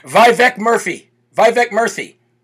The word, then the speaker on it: Vivek Murthy: But who we were giving the Dum Dum Award to, technically, is Surgeon General, current Surgeon General, illegitimate under the Biden administration, Vivek Murthy.